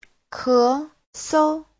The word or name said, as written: ke2sou5